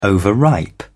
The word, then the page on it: overripe: British English